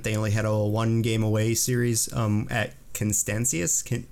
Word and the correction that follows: Canisius: How do you say that? Canisius.